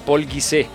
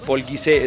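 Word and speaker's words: Pol Gise: Si quieren divertirse y les gusta la mitología griega contada en un punto que no es extenso y pesado, léanse a Paul Guisé. Ese Guisé se escribe G-I-S-E y el Paul es P-O-L, catalán.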